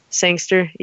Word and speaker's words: Sangster: I mean, my favorite character has got to be Newt, played by Thomas Brody Sangster, I think is how you say his last name, because of all the stuff he goes through. Sangster, yeah.